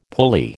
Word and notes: pulley: American English

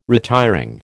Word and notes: retiring: American English

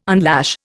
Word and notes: unlash: Indian

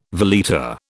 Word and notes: veleta: British English

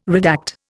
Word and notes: redact: Indian

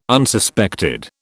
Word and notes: Unsuspected: British English

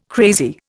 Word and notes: crazy: Indian

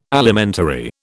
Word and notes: Alimentary: British English

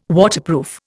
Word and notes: Waterproof: Indian

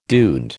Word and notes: duende: American English

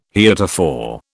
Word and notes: heretofore: British English

